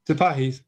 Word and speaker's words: Sipahis: So I've now finished all the janissaries, all the capoculi, so the nobility, all the generals, and all the heavy cavalry, the syphilis, or however you want to pronounce that one. – Sipahis. – I hope it's Sipahis, yeah.